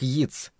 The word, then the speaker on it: Йейтс: По-моему, да, ответил Крайтон, обдумав и отвергнув мысль о том, чтобы сказать Старке, что фамилия этого поэта произносится как Йитс.